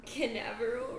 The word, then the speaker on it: Canaveral: I thought you were going to say I pronounced Canaveral wrong. Did I? – No, said it fine.